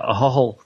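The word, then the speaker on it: Ahoghill: But yeah, a hole is like clearing your throat. It's A-H-O-G-H-I-L-L, A Hog Hill, a hole.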